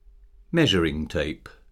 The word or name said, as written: measuring tape